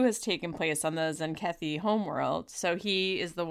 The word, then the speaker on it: Zenkethi: He's there to celebrate this promotion, but he's also there to tell Sisko that a coup has taken place on the Zenkethi.